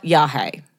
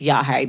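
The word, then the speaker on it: yagé: Or yahe. I'm pretty sure it's yahe, but it's like Y-A-G-E, but I think it's pronounced yahe.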